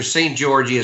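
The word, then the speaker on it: Szent-Györgyi: Well, it's Dr. St. Gorgi is how they pronounce right.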